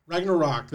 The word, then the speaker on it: Ragnarok: How do you pronounce that damn word? Ragnarok. There you go.